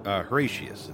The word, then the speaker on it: Horatius: How do you say Horatius? – Horatius. It's like Horatio with a U.S. instead of an O.